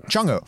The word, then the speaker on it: Chang'e: Eh, og den heter jo da Vi skriver det Chang E5, men jeg så en kineser si nå må dere skjønne, dere får prøve å uttale det riktig, og det er Chang O. E det er sånn de sier det.